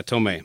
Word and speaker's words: Tomei: See, I was like Marissa Tomei, and for some reason my mind just said Tomei right there. But yeah, Tomei. All right, just want to make sure I say your name right. – Yeah, she's helped by her fame has helped out the pronunciation after years. I'm 59, so after years of it being mispronounced, which is obvious. – Yeah. – It's she's like, okay, great. Now you got it.